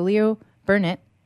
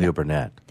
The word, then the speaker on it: Leo Burnett: Leo Burnett and Leo Burnett.